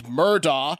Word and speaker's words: Murdaugh: I guess Murdaugh, spelled M-U-R-D-A-U-G-H, and Google pronounced Murdaugh, is pronounced Murdaugh.